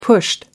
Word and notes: pushed: American English

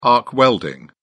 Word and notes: arc welding: British English

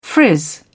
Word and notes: frizz: British English

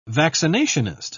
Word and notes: vaccinationist: British English

